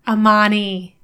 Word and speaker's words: Amani: Amani? – Amani. There we go. – It's Armani without the R.